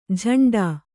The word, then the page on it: ಝಂಡಾ: jhaṇḍā